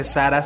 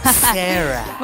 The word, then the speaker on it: Sarah: Pero bueno, Yami, cuéntanos de este epic fail y que no se dice Sara, se dice Sarah.